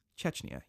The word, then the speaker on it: Chechnya: So basically they said, if we release these records involving our connections with the apartment bombings in Russia and our pitter-pattering in Chechnya, is that That's how you pronounce that country? Chechnya? What is it? I don't even know what it is. Let's see. Chechnya, yeah. They said,